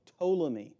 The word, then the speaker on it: Ptolemy: And in the south, their rulers went by the name of Ptolemy. It starts with a silent P like pterodactyl.